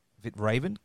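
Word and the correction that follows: Kvitravn: How do you pronounce it? – Kvitraven. – Kvitraven. Sorry. I apologize.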